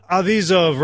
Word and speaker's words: Avizov: Gila, Lissert, David Donahoe, Blair Seibert, Doreen Avodzov. Okay, Doron. You beat me. Doron Avizov rhymes with Keep the Cheese Off.